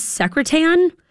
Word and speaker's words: Secretan: James Bond was almost named, and I might say this wrong, James Secretan. Like it looks like secretary, but ends with A-N.